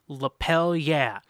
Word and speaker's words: Lapel Yeah: And our sponsor for today's episode is Lapel Yeah. Rhymes with hell yeah, as in, give me a lapel yeah.